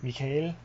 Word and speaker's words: Mikhail: A-L-A-I-N. – O-A-L-A-I, okay. – Yeah, I'm probably butchering it. – And it's Russian Michael, so Mikhail.